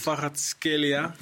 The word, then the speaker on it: Kvaratskhelia: Kvaratskhelia.